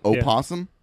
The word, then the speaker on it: opossum: Have you ever heard someone call it opossum? – Yeah. Opossum? – Yeah. – I mean, that's how it's spelled. – Yeah. – But you don't pronounce the O.